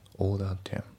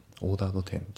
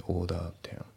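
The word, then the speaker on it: ordered: オーダーテン。オーダードテンじゃなくて、オーダーテン。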